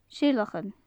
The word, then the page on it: seilleachan: gaelic